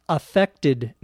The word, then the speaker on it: affect: Now, it's actually technically possible to use A-F-F-E-C-T as a noun also. It's pronounced differently when it is a noun. As a noun, it is affect.